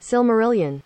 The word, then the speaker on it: Silmarillion: I believe it's pronounced Silmarillion. – We're gonna see in a second. Oh, they're not speaking. Oh, wait. – Silmarillion. – Silmarillion. All right, that's how they say it.